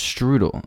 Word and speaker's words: strudel: And I got an apple strudel. – Oh, you said it right. – Strudel? Strudel. – Yeah, no, strudel.